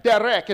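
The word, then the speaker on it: derek (דרך): Derek. It's actually spelled like the name Derek, but it's derek. That's a Hebrew word.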